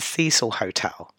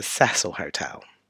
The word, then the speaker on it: Cecil: I'm just going to say that the Americans pronounce this as the Cecil Hotel, but I'm going to give it the English pronunciation, which is the Cecil Hotel.